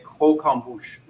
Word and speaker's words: croquembouche: That's not how you say it, you know. Yeah. It's, you say croquembouche, okay? Croque is to bite, and en bouche means in the mouth, okay?